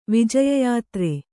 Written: ವಿಜಯಯಾತ್ರೆ

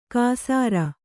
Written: ಕಾಸಾರ

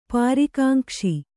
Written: ಪರಿಕಾಂಕ್ಷೆ

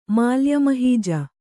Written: ಮಾಲ್ಯಮಹೀಜ

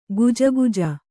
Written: ಗೊಜಗೊಜ

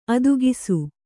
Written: ಅದಕ್ಷ

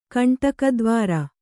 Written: ಕಂಟಕದ್ವಾರ

